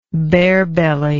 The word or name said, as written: barebelly